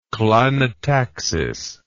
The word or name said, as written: klinotaxis